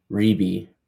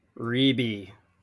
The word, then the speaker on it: Reebee: It is R-E-E-B-E-E. Rebe. – Rebe.